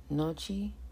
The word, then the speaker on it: Natchez: By the late 17th and early 18th century, the Natchez, pronounced Nochi, descendants of the Plaquemine culture occupied the site.